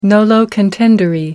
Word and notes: nolo contendere: American English

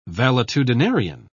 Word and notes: valetudinarian: British English

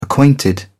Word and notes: acquainted: British English

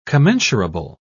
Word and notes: commensurable: British English

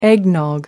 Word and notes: eggnog: American English

